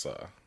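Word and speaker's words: Sa (蘇): S-O, but I think it's pronounced Sa. – Yeah. – Who could be wrong? Like, the O is pronounced like lot. So, yeah, Sa.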